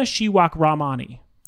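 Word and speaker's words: Shewakramani: So I picked Aparna Shirakrami. I almost said it wrong. Let me say it again. Aparna Shirakramani.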